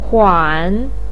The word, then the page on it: huan3: pinyin